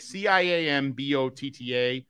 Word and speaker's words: ciambotta: So Jimbatha is what it's called. – Yeah, it's spelled like if you put like even if you phonetically spell like GIM, it's like CIAMBOTTA, ciambotta.